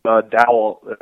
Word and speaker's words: Dowell: Yeah, Dowell, I guess is how his first name is pronounced, Loggins, was Dolphins offensive coordinator last year, and he will be following, as expected, Adam Gase to the Jets.